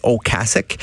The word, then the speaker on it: Ocasek: Euh, ben lui-même, il disait Rick Ocasek.